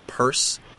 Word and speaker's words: Pierce: It would have been pronounced Purse Penniless. So it's spelled P-I-E-R-C-E. – But, you know, we're not pedantic. – But we don't say Purse Brosnan.